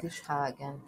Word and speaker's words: Friedrichshagen: Falei certo agora. – Friedrichshagen. Falou.